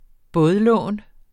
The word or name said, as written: bådlån